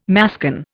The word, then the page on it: mascon: Indian